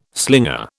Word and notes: slinger: British English

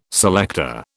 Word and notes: selector: British English

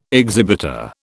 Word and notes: exhibitor: British English

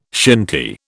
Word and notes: shinty: British English

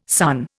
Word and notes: sunn: Indian

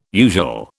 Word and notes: usual: British English

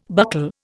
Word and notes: buckle: Indian

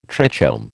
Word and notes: trichome: American English

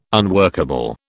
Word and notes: unworkable: British English

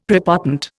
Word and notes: prepotent: Indian

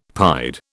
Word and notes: pied: British English

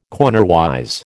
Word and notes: cornerwise: American English

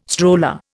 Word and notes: stroller: Indian

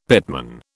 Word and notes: pitman: British English

